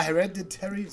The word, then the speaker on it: Hereditary: Also es gibt ja, es gibt ja Filme wie, äh, äh ich kann, kann, diesen Namen nicht aussprechen, hier Dietary T Hereditary. – Ja, Hereditary, so.